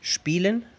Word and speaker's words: spielen: ¿Recuerdan la palabra spielen, jugar? S-P-I-E-L-E-N. Spielen. Solo se escucha la I larga, I no se escucha el diptongo ye. no se dice spielen. Ese diptongo no existe en alemán.